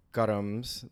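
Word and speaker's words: karam: Um, and I think the basic idea is that all of your karams, which is how you kind of really pronounce it, uh, are the things that you accumulate in this world.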